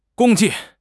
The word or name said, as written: 共计